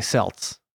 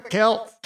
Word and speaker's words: Celts: Well, I say Celts, but obviously somebody out there's me like, it's pronounced Celts, you jerk. – Trick or treat, laddie. – The Celts. I say Celts because I think of the Celtics.